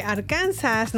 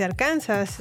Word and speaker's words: Arkansas: Está muy raro cómo pronuncian acá los nombres, porque Arkansas no se dice Arkansas. – Se dice Arkansas. – Ajá. – No se, pronuncia la S al final.